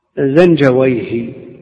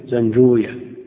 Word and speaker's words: زنجويه: زنجويه هكذا تنطق عند أهل اللغة، وكل ما ختم بويه، سيبويه، نفطويه، راهويه، أما أهل الحديث فيكون راهوية، زنجوية، سيبوية، كل هذا على خلاف ما عليه أئمة اللغة، والمسألة المرد فيها إلى أهل اللغة.